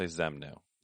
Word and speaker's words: Xemnu: And then I also picked Xemnu. That's how I'm thinking it's pronounced. Would you agree with that? – I would also say Xemnu. – Yeah, it's X-E-M-N-U, Xemnu.